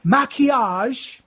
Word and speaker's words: Maquillage: So it's Ophelia, Ophelia, Maquillage. The L's are silent. So that's M-A-Q-U-I-L-L-A-G-E.